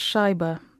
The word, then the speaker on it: Shaiba: Yeah, it's actually pronounced Shaiba, so anglicised Shaiba.